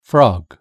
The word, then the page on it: frog: British English